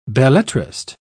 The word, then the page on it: belletrist: British English